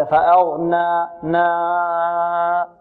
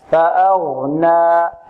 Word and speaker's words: فأغنى: وكذلك هنا نحذر من شيء آخر في الألف، وهو أن نشربها صوتا من الخيشوب لأنها جاءت بعد النون، لعل البعض ينطق هكذا فأغنى نا صوت من الخيشوم أغنى لا فأغنى فأغنى فأغنى فأغنى.